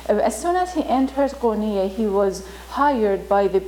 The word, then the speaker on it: Konya: So they settled into this area called Konya, now is in Turkey, and still they call it, the pronunciation is Konya.